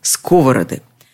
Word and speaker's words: сковороды: Только в именительном падеже множественного числа ударение переезжает на первый слог. Только здесь – сковороды.